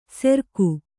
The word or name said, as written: ಸೆರ್ಕು